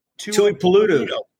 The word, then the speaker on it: Tuipulotu: The Bengals go out and draft the edge rusher, Tuli Tuipulotu. Am I saying that right? – Tuipulotu. – Tuipulotu.